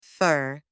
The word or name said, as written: fur